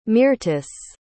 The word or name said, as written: Myrtice